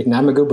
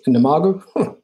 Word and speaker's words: Namogoo: By the way, I'm pronouncing it incorrectly. I guess I say Namogoo, but it's Namogoo.